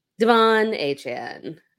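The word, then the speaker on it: De'Von Achane: Devon Achane, I said it right this time, 18 carries, 203 yards, two rushing touchdowns, four receptions, 30 yards, two receiving touchdowns.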